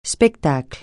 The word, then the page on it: spectacle: francais